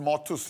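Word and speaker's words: martus (μάρτυς): It's spelt "martyrs" in Greek as well, but it's pronounced "martus" for the Greek scholars.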